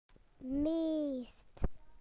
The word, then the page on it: most: British English